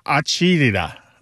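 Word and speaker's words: acidità: And it's pronounced something like acidita, acidita.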